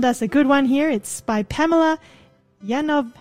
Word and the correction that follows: Yovanovitch: How do you say that? – Yovanovitch. Pamela, she was on our program not so long ago.